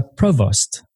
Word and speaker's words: provost: Kelly is also a provost.